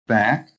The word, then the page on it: BACK: American English